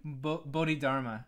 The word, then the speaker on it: Bodhidharma: Bodhidharma, I would imagine? – Bodhidharma.